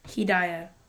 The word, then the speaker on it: Hedaya: It's not Harmon. It's H-Y-D-E-A or something. – Hedaya. – Spell it. – H-E-D-A-Y-A. – H-E-D-A-Y-A. Yeah.